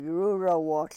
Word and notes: rurowohl: yurok

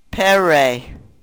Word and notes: perey: Yurok

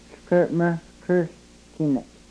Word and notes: hlkrmrkrhlkinek: yurok

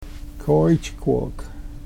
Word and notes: kooychkwok: Yurok

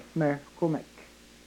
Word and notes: markumek: Yurok

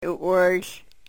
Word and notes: u-wryhl: yurok

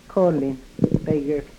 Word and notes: kolin pegrk: yurok

